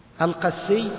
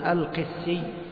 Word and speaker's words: القسي: القسي، هكذا نطقها بفتح القاف وتشديد السين، وهكذا يعني نطقها عند أهل مصر، فإن هذه يعني ثياب من الحرير تنسب إلى بلدة قس، وهي قريبة من تنيس على ساحل البحر الأحمر، فهي هكذا نطقها. قال أبو عبيد: وأصحاب الحديث يقولون بكسر القاف، يقولون القسي، نعم.